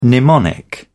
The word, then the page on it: mnemonic: British English